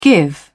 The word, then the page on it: give out: British English